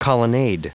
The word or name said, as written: colonnade